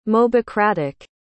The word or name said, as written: mobocratic